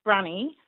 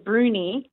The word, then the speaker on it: Bruny Island: Well, I think it's pronounced Brunny. – Right. – But I think some people also pronounce it Bruny.